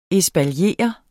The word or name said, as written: ESPALIERE